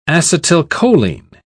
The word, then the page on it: acetylcholine: British English